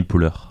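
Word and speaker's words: Poehler: Ça se dit Amy Poller comme euh, poulet Beer, euh, mais, sans le beer à la fin.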